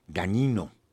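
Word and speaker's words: Dagnino: Perdóname, es el titular del SAT, Antonio Martínez Gagnino, así se pronuncia, es un apellido italiano, GN.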